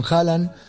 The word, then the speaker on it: Haaland: Noruega es una selección que yo le prestaría muchísima atención con Sorloth, con Haaland. – Haaland. – Con Odegaard. ¿Cómo? – Haaland. – No, Haaland. No, Haaland. ¿Lo escuchaste? No. ¿Lo escuchaste cómo se pronuncia? Haaland.